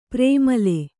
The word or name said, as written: ಪ್ರೇಮಲೆ